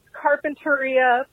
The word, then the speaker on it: Carpinteria: It's pronounced Carpinteria. Please. Don't butcher the city I grew up in.